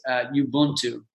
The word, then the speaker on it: Ubuntu: And it's, I don't know exactly how to pronounce it. I think it's Ubuntu, U-B-U-N-T-U, Ubuntu.